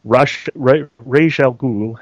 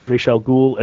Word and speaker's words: Ra's al Ghul: As long as I can remember, the name Ra's al Ghul has been pronounced Ra's al Ghul. Yet thanks to the Nolan trilogy, people have been screwing it up calling him Ra's al Ghul.